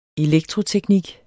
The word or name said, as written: elektroteknik